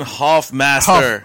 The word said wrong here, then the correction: Huffmaster: Huffmaster. We kept saying his name wrong. His name's Huffmaster. It's a U.